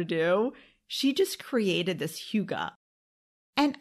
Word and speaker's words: Hygge: It's spelled H-Y-G-G-E, okay? So it looks like it's spelled Hygge, but according to Google, it's pronounced Hygge.